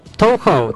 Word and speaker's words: Touhou: Nie wiem, czy dobrze go wymawiam. Touhou. – Touhou, tak? – Touhou Project. Touhou, przez samo H, Touhou Project.